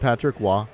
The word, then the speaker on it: Patrick Roy: It is pronounced Patrick Wah. – Wah. – And it does not change when you cross the border.